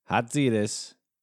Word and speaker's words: Hatzidis: Our guest today is Niki Hatzidis.